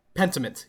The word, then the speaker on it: Pentiment: Then we had, I don't know if I'm pronouncing this right, Pentiment? – Pentiment, yeah.